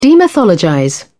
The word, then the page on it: demythologize: British English